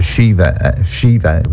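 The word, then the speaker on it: Síomha: It's a girl called Shiva, which is spelled S-I-O-M-H-A.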